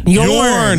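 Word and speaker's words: Jørn: Jorn. It's Norwegian. – The J is silent.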